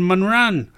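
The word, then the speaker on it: Mànran: And Manran. It's got an accent on there. I keep missing it. It's on the first syllable.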